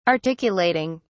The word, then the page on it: articulating: British English